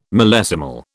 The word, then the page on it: millesimal: British English